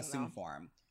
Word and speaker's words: Assume Form: And he dropped a recent album, Good Forum. I feel like I messed that up. Hold on now. I'm so sorry. – Album's yes. amazing. – Assume Form.